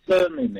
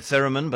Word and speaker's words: theremin: Do you know what a thermim is? T-H-D-R-E-M-I-N. – Yeah, I would have said theremin, but I don't think we should argue about the pronunciation.